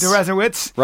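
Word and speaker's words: Deresiewicz: Ladies and gentlemen, our guest, the author of Excellent Sheep, an excellent book, is Professor William Derezowitz, whatever. – It's Derezowitz. – Derezowitz. – Rhymes with Jesuits.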